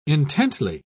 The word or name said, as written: intently